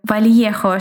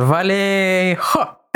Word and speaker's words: Вальехо: Но 4 июня 1969 года в городе Вальехо, штат Калифорния, у меня большие проблемы с произнесением названия этого города, поэтому, пожалуйста, поправляй меня по ходу дела. – Думала, что она произносится Валехо. Да, мне как-то...